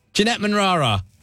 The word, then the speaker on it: Jeanette Manrara: Jeanette Manrara. Did I say that right? Jeanette Manrara. Yeah, good, yeah.